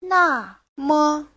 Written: na4me5

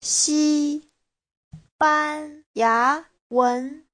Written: xi1ban1ya2wen2

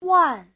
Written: wan4

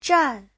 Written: zhan4

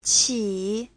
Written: qi3

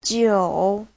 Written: jiu3

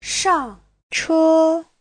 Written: shang4che1

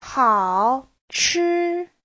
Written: hao3chi1

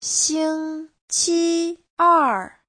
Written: xing1qi1er4

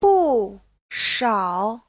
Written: bu4shao3

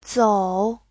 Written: zou3

